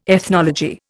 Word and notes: ethnology: Indian

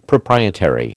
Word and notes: proprietary: American English

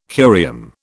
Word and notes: curium: British English

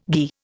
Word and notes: ghi: Indian

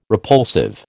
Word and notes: repulsive: American English

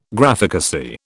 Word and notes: Graphicacy: British English

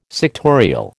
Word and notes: sectorial: American English

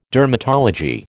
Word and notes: dermatology: American English

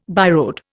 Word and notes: byroad: Indian

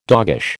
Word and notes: doggish: American English

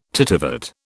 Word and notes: tittivate: British English

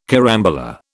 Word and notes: carambola: British English

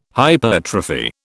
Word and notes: hypertrophy: British English

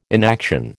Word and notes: inaction: American English

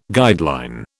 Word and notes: guideline: British English

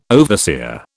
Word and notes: overseer: British English

